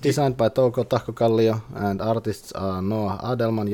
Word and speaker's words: Tahkokallio: That's the way how you pronounce that, all of you, if anyone listens. It's Lautapelit.fi or .fi, and it's Touko Tahkokallio.